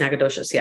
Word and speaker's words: Nacogdoches: I'm glad you said that because I have no idea how to pronounce that. You lived in Texas, so yes, you know. – I had friends that went there. I just couldn't remember. Yeah, but it's Nacogdoches.